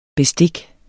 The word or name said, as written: bestik